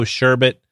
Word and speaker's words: sherbet: And this week, I got the Chonk Rainbow Sherbet Smoothie Sour. And notice that I did say sherbet, because there's only one R in sherbet. So for those of you that spell it sherbert, that's not correct.